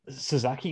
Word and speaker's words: Sasaki: Did they build to a rematch between Sasuke and Kawada? – Sasaki and Kawada. – Oh, yeah. Sasaki and Kawada. Sorry, I was wondering if you meant Nagata and Kawada.